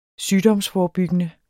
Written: sygdomsforebyggende

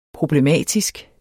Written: problematisk

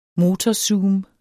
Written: motorzoom

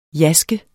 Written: jaske

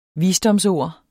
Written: visdomsord

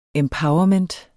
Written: empowerment

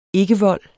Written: ikkevold

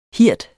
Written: hird

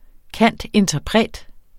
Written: cand.interpret.

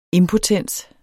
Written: impotens

